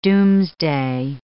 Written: doomsday

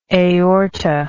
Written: aortal

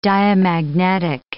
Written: diamagnetic

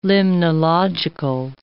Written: limnological

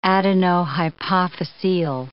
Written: adenohypophyseal

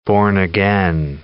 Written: born-again

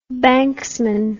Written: banksman

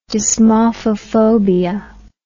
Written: dysmorphophobia

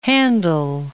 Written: Handel

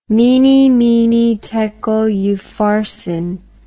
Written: mene mene tekel upharsin

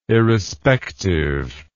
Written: irrespective

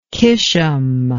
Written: qishm

